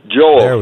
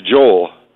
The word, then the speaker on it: Joel: Joel. No, go. My name is Joel. Two syllables.